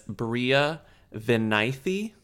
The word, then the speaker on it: Bria Vinaite: So she's, um, her name is, uh, gotta pronounce her name right. Her name is Bria Vinaite, according to an interview that I saw with her where the guy where the woman says her name.